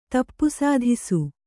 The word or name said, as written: ತಪ್ಪುಸಾಧಿಸು